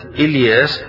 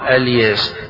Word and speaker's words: إلياس: فهنا يقال في القراءة اللي فيها قراءة الجمهور وإن إلياس لمن المرسلين، قراءة ابن عامر بيقول فيه وإن الياس وإن الياس، فإذا بدأ بكلمة إلياس الجمهور يبدأون إلياس، وإذا بدأ ابن عامر قال الياس.